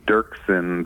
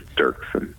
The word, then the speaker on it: Doerksen: Dirksen, the O is silent, but it's spelled D-O-E-R-K-S-E-N. And yeah, I get all kinds of funny pronunciations of my last name. So, but it's just Dirksen.